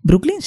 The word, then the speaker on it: Brooklyn: ¿Brooklyn? Sí, ¿no? Lo digo bien. Uh-huh.